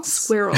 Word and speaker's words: squirrel: Squirrel. I always pronounce it. – But you know that supposedly... – I pronounce it like it rhymes with girl, you know, which is a little bit reduced, but still fairly intact.